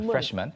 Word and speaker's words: freshman: 그런데 이게 한 단어가 되면서 그렇게 freshman이라고 하진 않아요. – No, freshman. – man처럼 발음합니다. freshman.